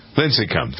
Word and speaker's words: Lincecum: Lincecum.